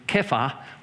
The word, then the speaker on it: Kepha: He has three names, Simon, son of John, Kepha, or Cephas, we pronounce it, in Aramaic, Kepha, and Peter in Greek, it means rock.